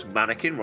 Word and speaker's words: Mannequin: And of course, Mannequin rhymes with Anakin. It does.